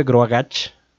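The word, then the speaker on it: Grogoch: Se escribe Groagach, pero todo el mundo le decía Groagach con ese como al final. Entonces, así es como se llama.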